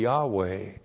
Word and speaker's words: Yahweh: If you pronounced it, it would be Yahweh. They never pronounced that. They saw the Tetragrammaton and always read Adonai.